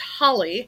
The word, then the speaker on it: challah: Yes, and it does have challah in it. – Although pronounced holly to rhyme with bialy. – Which is a very New York City way to pronounce challah.